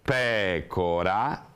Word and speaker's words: pecora: Allora abbiamo pecora, classifichiamo questa parola con un accento tonico che cade sulla terz'ultima sillaba, anche se in questo caso è la prima, e questa è una parola grammaticalmente definita sdrucciola.